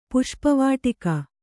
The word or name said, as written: ಪುಷ್ಪವಾಟಿಕೆ